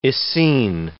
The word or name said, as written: Essene